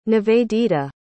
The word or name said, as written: nivedita